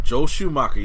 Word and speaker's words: Joel Schumacher: But first, Joel Schumacher. Did I say it correctly? – Joel Schumacher, yes.